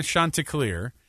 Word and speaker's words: Chanticleer: Now, I read the actual pronunciation, too. It's a weird, they say it's said like this. The Coastal Carolina Chanticleer, which is like on their logo is like a very vibrant bluish teal rooster, okay?